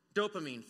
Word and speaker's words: dopamine: The article goes on to say, engaging in behaviors that help others releases neurotransmitters such as dopamine, I don't know how the word's pronounced. Dopamine, thank you.